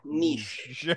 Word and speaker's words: niche: Yeah, niche. that's how you pronounce that.